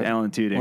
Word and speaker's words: Alan Tudyk: Alan Tudyk.